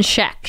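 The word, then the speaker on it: Schaech: And Jonathan Shayuch, however you say his name, as Richard Fenton. He's playing the killer. How do you say it? – Jonathan Scheck. – Scheck? Scheck. Okay.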